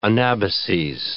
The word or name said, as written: anabases